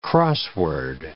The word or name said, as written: crossword